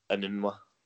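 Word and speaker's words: Enunwa: I got he's got Mnemu, is that how you pronounce Inunua.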